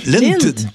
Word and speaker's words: Lindt: Prononce-tu le T? – Lindt.